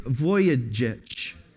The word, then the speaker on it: Vujicic: His name is Nick Vujicic.